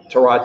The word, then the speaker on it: Tyrod: I think Tyrod Taylor is going to have a fine season. – Hey, props on getting the new pronunciation. – Tyrod. I know, Tyrod.